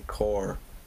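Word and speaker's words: corps: But you don't pronounce the P there, so you just say core.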